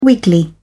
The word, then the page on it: wiggly: British English